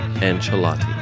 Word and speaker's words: Ancelotti: I am happy to inform you that the C in this man's last name should be pronounced the same way you pronounce the CH at the beginning of Chip or Chelsea or at the end of the phrase delicious Italian sandwich. Your Everton manager none other than Carlo Ancelotti.